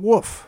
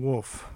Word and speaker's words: wolf: I thought it was a silent L in wolf. – Oh, interesting. – But apparently you're supposed to say the L.